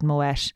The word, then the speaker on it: Moët: It's pronounced Moët, by the way. That's the night I learned how to pronounce Moët. To be fair, it did teach me that life lesson.